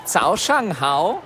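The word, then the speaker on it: Zao Shang Hao (早上好): Oder besser, Zao Shang Hao.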